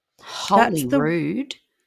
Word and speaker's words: Holyrood: She's got Holyrood or I don't know how to pronounce this, Holyrood Palace. – Holyrood? – Yeah, it's spelled H-O-L-Y-R-O-O-D.